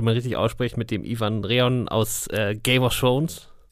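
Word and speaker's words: Iwan Rheon: Ja, ähm, also es gibt ganz viele verschiedene Aussprachen äh, von Ivan, Ivan, Ewan, und bist noch ganz verrückte andere Dinge, die ich gehört habe. Ich glaube, Ewan Rian ist ähm, so, wie er sich auch selber nennt.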